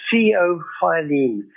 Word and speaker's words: theophylline: Now, one of the most commonly used drugs is called, and I'm going to spell it after I've pronounced it, it's theophylline. Theophylline. So having spelled that, we pronounce it theophylline.